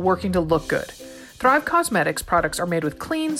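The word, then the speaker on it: Causemetics: It's because it's not cosmetics, it's cosmetics. Cause is in the name for a reason.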